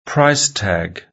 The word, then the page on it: price tag: British English